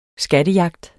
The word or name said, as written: SKATTEJAGT